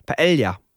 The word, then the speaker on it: Paella: Paella. Also ich finde, der Doppel-L sollte man ja immer so wie L und J aussprechen.